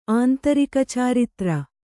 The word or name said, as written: ಆಂತರಿಕಚಾರಿತ್ರ